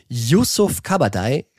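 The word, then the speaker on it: Yusuf Kabadayı: Yusuf Kabadai steht in München vor dem Absprung. Is it true or not true? – Das ist true. True. Erstmal natürlich Kompliment. Yusuf Kabadai hast du hervorragend ausgesprochen.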